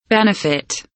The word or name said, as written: benefit